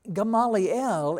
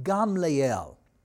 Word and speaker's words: Gamliel: But now, Gamaliel in Hebrew is pronounced Gamliel.